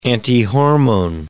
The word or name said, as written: antihormone